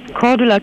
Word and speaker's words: Cordula: Cordula Kienes.